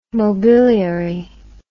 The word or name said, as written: mobiliary